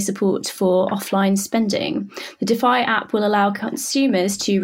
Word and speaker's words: Dify: Opera is moving into financial services with the launch of DeFi, that's spelled D-I-F-Y, but it's pronounced DeFi, an in-browser cashback service and digital wallet for online shopping.